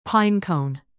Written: pine-cone

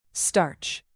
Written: starch

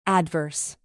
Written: adverse

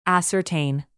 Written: ascertain

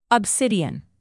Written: obsidian

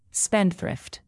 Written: spendthrift

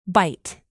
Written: byte

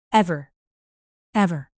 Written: ever

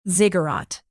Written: ziggurat

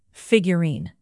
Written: figurine

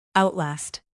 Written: outlast

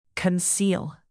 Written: conceal

